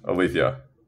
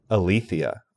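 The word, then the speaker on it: Alethea: Yeah, Aletheia. – Aletheia.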